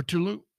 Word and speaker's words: Tulum: or Tulum.